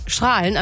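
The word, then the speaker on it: Straelen: Nicht wie bei Strahlen am Niederrhein, dass er mit A ausgesprochen wird.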